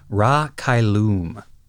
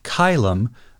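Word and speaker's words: Ra Cailum: Interestingly, the English dub for this movie pronounces the ship's name as Ra Kailum. I find this decision fascinating because it's arguably closer to the classical Latin pronunciation for the word that we think they've borrowed, but it's further from the Kairamu of the Japanese. The way we're saying it, Kailum, feels kind of like a midpoint between those two.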